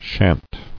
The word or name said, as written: Shan't